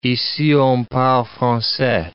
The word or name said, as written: ici on parle francais